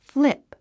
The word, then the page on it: flip: flíp; フリップ